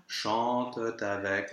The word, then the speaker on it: chante avec: Now, here, chante is a verb in the third person of the plural, so that ENT ending is actually just a schwa with a silent T, which means that we can have the liaison after.